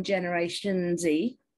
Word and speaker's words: Generation Z: I am hopeful that we're going to see more pushback from Generation Z. I'm using the American pronunciation here because people, Generation Z, seem to do that now.